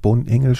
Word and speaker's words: Bonengel: Es gibt ja eine wichtige Figur, die da eine Rolle gespielt hat, das ist ein Filmemacher, Winfried Bohnengel spricht man ihn aus. – Winfried ne? Bohnengel, genau. Bohnengel, genau.